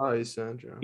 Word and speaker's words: Yxandro: Wait, so is it Yxandro? Am I saying it right, or is it Yxandro? – My name's Yxandro. Yeah. – Yxandro. – Oh, Yxandro.